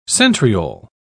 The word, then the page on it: centriole: British English